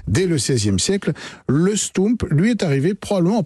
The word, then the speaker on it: stoemp: Alors stoump, ça s'écrit S-T-O-E-M-P, et ça se prononce stoump, pourquoi? Parce que O-E... En Belgique, en flamand, ça se prononce ou comme Pouls, comme Poulvorde, comme un certain nombre d'autres exemples.